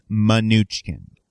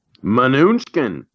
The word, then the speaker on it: Mnuchin: So we should clarify, according to the internet, which is never wrong, his name is pronounced Mnuchin. – Mnuchin.